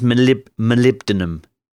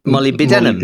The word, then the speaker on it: molybdenum: Molybdenum. I actually got a message from someone saying, no, you completely screwed that up. This is how you say it. And I think it comes out that it's molybdenum.